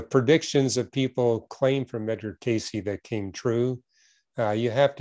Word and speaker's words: Cayce: Yeah, usually it's pronounced Edgar Cayce.